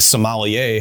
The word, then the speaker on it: sommelier: And they see cool inclusions and they assign cool color grades, but their eyes have been like a sommelier has to develop their skill at. – You pronounce that one right.